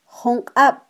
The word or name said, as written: junqab